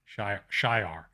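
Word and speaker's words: Shi'ar: The connections that haven't happened yet, X-Men, obviously, the She-Ra, which have been rumored for the MCU for a long time, the Shi-Ra. Is that how you say it? Shi-Ar.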